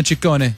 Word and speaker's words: Ciccone: What is it, sir? – Well, in Italian, it works the other way. So the double C's are hard C's and the singular C's are soft C's. – So Ciccione. – Ciccone. – Ciccone. Madonna Ciccone. God, why'd she drop that? That's good. It's Ciccone.